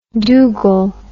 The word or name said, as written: dougal